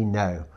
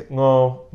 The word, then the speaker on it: Ngo: His name is Andy No. I know I've pronounced it wrong, Andy. Just say it once so we know how it's really pronounced. Andy. – The Vietnamese way is Ngo, but I accept No.